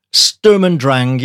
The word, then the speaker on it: Sturm und Drang: And today we are listening to the 2015 Lamb of God album, and I hope I pronounce this correctly, Sturm und Drang. – Sturm und Drang. Yeah, that's about right.